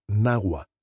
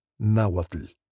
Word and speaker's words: Nahua: It isn't pronounced as it's written. We say Xochitl, and the language is Nahua, not Nahuatl. The name Xochitl appears only a few times in the novel, but the emperor Axayacatl comes up constantly.